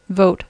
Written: vote

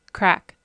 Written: crack

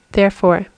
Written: therefore

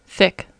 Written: thick

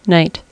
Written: night

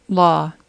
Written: law

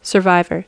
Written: survivor

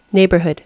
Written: neighbourhood

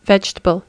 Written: vegetable

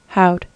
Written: how'd

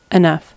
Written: enough

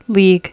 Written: league

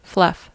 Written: fluff

